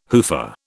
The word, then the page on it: hoofer: British English